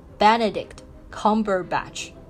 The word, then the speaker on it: Benedict Cumberbatch: 所以呢，教大家来念一下本尼迪克特康伯巴奇的名字啊，他这个名字中文听上去就非常的二缺。那他名字的英文的发音呢，就是 Benedict Cumberbatch。